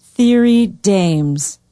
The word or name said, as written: thiry daems